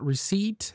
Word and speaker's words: receipt: If you don't know about the silent letter, you might spell the word island, just that I-L-A-N-D. Similarly, the word receipt has a silent P at the end, which can trip up even native speakers of English.